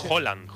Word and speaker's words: Haaland: Es Haaland. – Como si dijeras un queso, ¿no? – ¿Sabe qué pasa el martes?